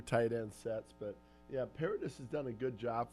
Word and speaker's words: Paradis: Did I say that right, Paradis? – Yeah. Paradis is good now.